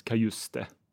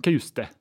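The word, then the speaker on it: Cajuste: Jag skulle ljuga om att säga att det inte är Jens Kajuste. – Mm. Som det tydligen ska uttalas, ja. – Ja, Kajuste. – Ja, jag hörde att det ska vara betoning på E-t också.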